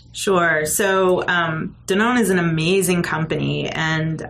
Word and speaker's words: Danone: Now, Danone is a company.